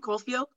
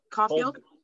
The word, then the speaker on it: Caufield: Caufield. – Caufield? – Cold, cold Caufield.